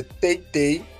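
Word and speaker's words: teytey.is: O Instagram dele é teitei.is.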